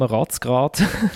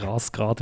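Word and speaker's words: Razgrad: Und aber gegen einen weiteren Club, den ich toll finde, wegen dem Namen Ratzgrad, also nein, Ratzgrad wird sich ausgesprochen.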